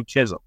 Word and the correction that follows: Schism: That whatever that song, you know, Schism. – Yeah. – Sorry if I even mispronounced it.